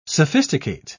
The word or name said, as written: sophisticate